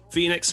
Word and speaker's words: Fenyx: I believe so. Phoenix, spelled F-E-N-Y-X.